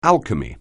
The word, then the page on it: alchemy: English